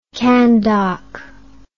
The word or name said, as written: candock